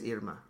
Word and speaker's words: Irma: Hurricane Irma, which actually technically it is Irma, right? That's how you would pronounce it in Spanish.